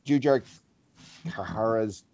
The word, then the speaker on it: Jujhar Khaira: So if I heard Jujhar Khaira's name pronounced correctly it's not a third syllable in there, but the H is squeezed in there somehow.